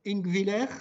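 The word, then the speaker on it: Ingwiller: Donc, Gérard Sigwald, petite présentation, fils de pasteur luthérien, vous êtes né à Ingviller, c'est comme ça qu'on prononce, dans, dans les années 30, voilà, vous ne révélerai pas tout.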